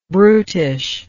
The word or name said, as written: brutishness